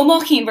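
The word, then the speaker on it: Ó Maicín: So will you tell us a little bit about, am I saying this right? The O'Mason versus Ireland? – Oh, O'Mochin versus Ireland.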